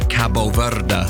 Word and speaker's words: Cabo Verde: I think the Portuguese pronunciation would be Cabo Verde.